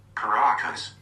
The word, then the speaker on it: Caracas: Caracas.